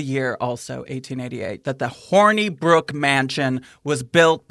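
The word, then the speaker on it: Hornibrooke: And also, it's kind of cute the way it's spelled. It's H-O-R-N-I-B-R-O-O-K-E. Horny Brook. Like, I like the I like that. Yeah, yeah, it's like horny, but it's like a little more innocent.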